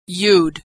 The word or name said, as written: you'd